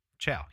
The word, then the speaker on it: Chau: Uh, in November of 2018, John Allen Chau Chau Chau Chau have I been saying that right this whole entire time? Sure. Sure. Chau. Yeah, John Chau.